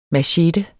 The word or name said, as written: machete